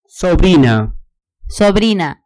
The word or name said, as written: sobrina